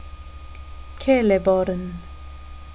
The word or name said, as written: celeborn